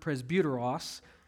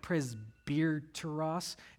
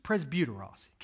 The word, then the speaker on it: presbyteros: There's elders, and that's the Greek word presbyteros. That's how you pronounce it. I know the English looks like presbyteros. It's just presbyteros.